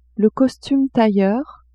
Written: costume tailleur

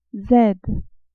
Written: z

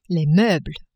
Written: meubles

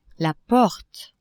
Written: porte

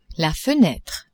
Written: fenetre

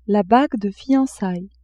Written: bague de fiancailles

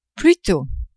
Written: plutôt

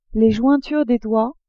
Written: jointures des doigts

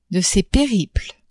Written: de ses periples

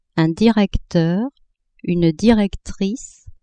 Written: directeur-directrice

